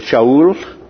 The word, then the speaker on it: Shaul: His Hebrew name would have been Shaul, is how they would have pronounced it.